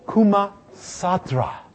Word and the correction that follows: Kama Sutra: Yes, not too many have heard of, actually, Kama Sutra. I messed up those syllables there. Okay, let's see the hands now. Ah, come on. Yes, yes. Interesting. Good. Kama Sutra, that worm, that malicious worm that some diabolical mind created to infect computers.